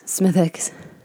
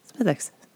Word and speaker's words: Smithwick's: And that is Smithwick's, which is spelt S-M-I-T-H-W-I-C-K apostrophe. Yeah, no apostrophe S. Smithwick's. Yes, Smithwick's.